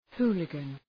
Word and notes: hooligan: ‘hu:lıgən

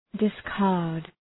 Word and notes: discard: dıs’kɑ:rd

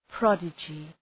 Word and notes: prodigy: 'prɒdıdʒı

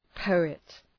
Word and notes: poet: ‘pəʋıt

